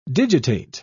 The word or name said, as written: digitate